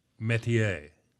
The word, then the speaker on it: métier: It's spelled M-E with the angled emphasis note over the top, and T-I-E-R, métier, a French word.